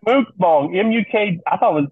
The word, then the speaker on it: mukbang: Is that B-A-N-G? Like a designer dog? – It's like a. we are so off the rails. It's M-U-K-B-A-N-G, pronounced mukbang.